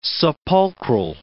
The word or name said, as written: sepulchral